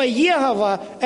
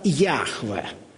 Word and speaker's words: Яхве: Но слово Йегова, это такого слова не существует. Если уже говорить правильно, то этот тетраграмматон произносится как Яхве.